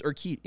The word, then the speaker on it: Urquidy: It's Urquidy. Yeah, you're saying it. You're saying it close enough, I think.